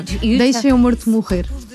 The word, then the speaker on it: Deixa Meu Morto Morrer: It's in Portuguese, it's very hard to pronounce Portuguese, but it's almost. – No, you Deixa Meu Morto Morrer.